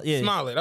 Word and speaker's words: Smollett: But yeah, how do you pronounce his last name for real? – Smollett, okay.